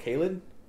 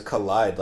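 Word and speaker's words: Kaleid: Is it pronounced Kaled? Like the instrumental track? – I always thought it was Collide, like a kaleidoscope.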